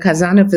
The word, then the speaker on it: Khazanov: And Kazanov is spelled with K-H-A-Z-A-N-O-V.